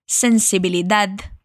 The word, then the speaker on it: sensibilidad: Y aquí quiero practicar contigo la pronunciación de la D al final de la palabra. Vas a dejar tu lengua suave entre tus dientes de arriba y los de abajo, perfecto. Repite conmigo. Sensibilidad. Ahora la otra, amabilidad.